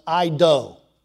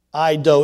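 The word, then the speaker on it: I-do (εἴδω): It's pronounced I-do. I-do.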